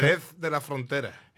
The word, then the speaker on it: Jerez de la Frontera: Jerez de la Frontera.